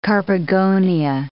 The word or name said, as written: carpogonia